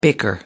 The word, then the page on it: bicker: American English